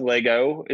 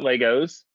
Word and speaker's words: Lego: And it's pronounced Lego. It's not Legos. Lego plural is Lego.